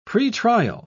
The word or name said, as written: pretrial